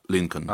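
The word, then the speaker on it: Lincoln: Кстати, вот в слове Линкольн, чтобы вы знали, тоже Л одна. Линкольн. – А, не читается. Линкольн, да.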